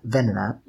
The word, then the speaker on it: Venonat: Now, Venonat is... Why did I say it like that? Venonat.